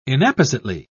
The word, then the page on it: inappositely: British English